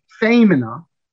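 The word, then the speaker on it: fēmina: For example, the word F long E M I N A, famina, means, anyone know?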